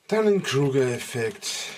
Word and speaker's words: Dunning-Kruger-Effekt: Dunning-Kruger-Effekt.